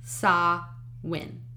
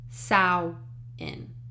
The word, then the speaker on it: Samhain: And yes, it is pronounced Samhain. In Ireland, it's pronounced Samhain. And in Scotland, it's pronounced Samhain.